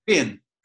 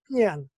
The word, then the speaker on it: paean: And the piece of music of yours, I can never pronounce properly, P-A-E-A-N. – Pian. – Pian. – Yeah, Pian.